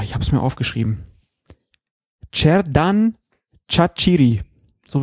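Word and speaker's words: Xherdan Shaqiri: Äh, auf der letzten Silbe wird betont im Albanischen und deswegen, ähm, ja, heißt der Mann dann Cerdan Chachiri irgendwie.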